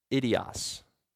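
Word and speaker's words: idios (ἴδιος): If you look in the Greek, if you transliterate that word own, you take the Greek word and you pronounce it like in English, it's the word idios. Sounds a lot like idiot, doesn't it?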